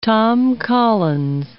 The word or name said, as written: Tom Collins